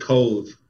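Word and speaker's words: Cobh: left out of Cobb. Is that right? – Yeah, and we pronounce it Cove, Chris.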